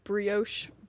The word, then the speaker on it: brioche: But like a good chicken sandwich, like brioche bun, is that how you say it? – Yeah.